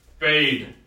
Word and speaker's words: bade: The suspicious god then baited. How do you pronounce that word? Baited? B-A-D-E? Batted? – Bade. – What? – Bade. – Bade? – Yes.